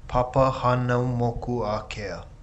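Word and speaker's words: Papahānaumokuākea: Papa Hanamoku Akea, pronounced slowly, is Papa Hano Moku Akea.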